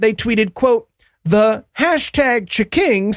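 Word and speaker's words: Ch'King: And Burger King, as a part of that war, has introduced the Chiking Chicken Sandwich. – Damn it. – That is C-H apostrophe K-I-N-G. That's what they went with for the name of their sandwich.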